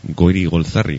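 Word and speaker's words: Goirigolzarri: Goiri Golzarri,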